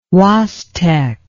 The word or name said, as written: Huastec